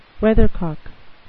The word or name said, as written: weathercock